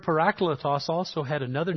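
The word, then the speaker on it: Parakletos (παράκλητος): One of the favorite names that is used of the Holy Spirit in the New Testament is the word parakletos. Maybe you've heard it pronounced paraclete. That's not how it's pronounced in the original language. Parakletos.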